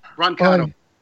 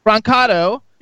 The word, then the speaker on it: Brancato: Brancato. – Brancato.